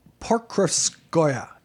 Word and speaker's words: Pokrovskoye: Pokrovskoye is apparently how you pronounce the name of his village.